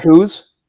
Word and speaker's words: coo: They call cows coos? – That's how they pronounce it, man. – Oh my God, I didn't know that. – And they actually spell it COO.